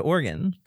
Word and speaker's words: Oregon: And maybe we can go to Oregon.